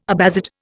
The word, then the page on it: Abbasid: Indian